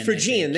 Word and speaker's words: Phrygian: Phrygian, thank you.